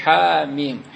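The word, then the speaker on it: حم: دا تلفظ بیا داسې ونه کړي چې حامیم، دا میم نه راټینګېږي لکه د الف لام میم، دا چې دي پر هغه خو شت شته الف لام میم ویل کیږي،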